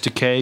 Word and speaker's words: Takei: It's Takei because it rhymes with William Shatner's toupee.